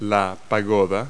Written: pagoda

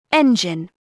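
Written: engine

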